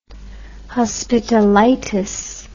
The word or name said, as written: hospitalitis